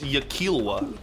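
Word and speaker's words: yklwa: Is it spelled Y-K-L-A-W-A? – Oh, yeah. Yikowa. – I think it's pronounced Yikilwa.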